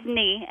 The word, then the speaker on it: Nee: It's Nee, N-E-E.